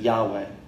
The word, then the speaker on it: Yahweh: And we pronounce it as Yahweh.